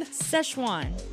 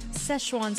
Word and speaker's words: Szechuan: So how do you pronounce this again? Szechuan? – Szechuan. Szechuan sauce.